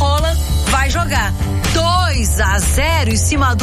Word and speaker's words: Haaland: Lembra que a gente falou sobre a pronúncia do nome do Haaland, que era Haaland? – Aham.